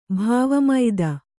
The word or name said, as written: ಭಾವಮೈದ